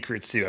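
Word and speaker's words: Creed II: Why the fuck would I see Creed's too? I believe is what I pronounced. – Correct.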